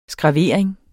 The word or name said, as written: skravering